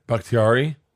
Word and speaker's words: Bakhtiari: Bakhtiari.